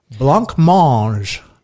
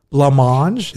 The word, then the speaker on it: Blancmange: So now we move to number 32, Blancmange, Living on the Ceiling.